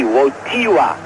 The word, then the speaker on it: Wojtyła: The accent is on the T. Wojtyła. Although you spell it W-O-J-T-I-L-A. Wojtyła.